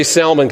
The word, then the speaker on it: Salmon: Now, I want to say salmon, but that sounds a little fishy, you know. but I don't know. Yeah, sorry. Ba-doom! Where's my drum? It's spelled that way, S-A-L-M-O-N. So I'm going to say salmon, because if I say salmon all morning, I'm going to want to go fishing.